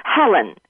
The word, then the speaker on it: Helen: Helen, H-E-L-E-N, from California.